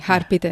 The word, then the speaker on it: Harpreet: Diamo il benvenuto allora alla nostra, os- ospite, alla nostra ospite Harpreet Tandai. Ho detto bene? – Buongiorno, sì, Harpit è giusto, grazie Carlo.